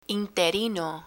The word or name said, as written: interino